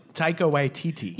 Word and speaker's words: Taika Waititi: Oh, Taika Waititi. – Yeah, and I didn't want to fuck that up. And Jennifer Caitlin Robinson is credited. Who directed it? – Taika Waititi.